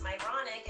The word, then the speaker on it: Myronuk: even though my last name is pronounced Myronuk and people have these Myronuk experiences, I give all the humor to that of God.